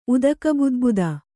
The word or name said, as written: ಉದಕಬುದ್ಬುದ